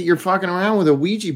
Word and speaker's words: Ouija: The little girl finds a Ouija board and I think that's how you're supposed to pronounce it. Ouija. – Whatever. – That's how they pronounced it in the movie. Ouija.